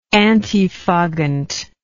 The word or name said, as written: antifoggant